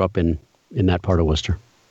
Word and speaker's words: Worcester: I grew up in South Worcester, Mass.